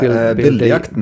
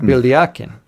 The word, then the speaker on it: Billjakten: You said you've got this idea in play and it's called something like Billjedikin. Is that pretty, am I getting close? – Oh, yeah. Billjakten. – Billjakten. Billjakten. Yeah, your pronunciation's pretty good, so well done.